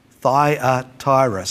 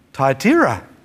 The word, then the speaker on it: Thyatira: Today, we're looking at this issue through the grid of the church at, I was taught to say, always taught to say, Thyatira. So four syllables. Logical, isn't it, from an English-speaking perspective? But when we're in Turkey, our guide called it Thyatira. And I've been trying to learn Thyatira ever since, but I find it goes so much against my grain. But I guess his pronunciation was probably closer to what the original pronunciation may have been like.